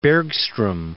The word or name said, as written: Bergstrom